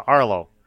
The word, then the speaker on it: Arlaux: And I said, but it's spelled A-R-L-A-U-X. He said, oh, that's cool. So yeah, he writes, ghoulishly yours, Arlo. You know, John Russo. I said, yeah, you would be surprised how many people mispronounce her name. And he's like, well, A-U-X is O.